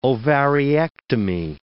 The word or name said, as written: ovariectomy